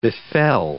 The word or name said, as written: befell